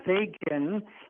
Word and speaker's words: Fagan: which is Fagan. And I said, how does he spell that? I say, I spell mine F-A-G-A-N.